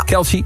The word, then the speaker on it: Kelce: samen met Travis Kelce, zeg ik dat goed? – Kelce, ja.